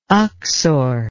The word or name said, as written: uxor